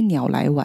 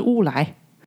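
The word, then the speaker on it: 烏來: 要不要去鸟来玩？变成我纠正他说是乌来。中文字真的是很奥妙，小小的地方差一笔画，读音就完全不同。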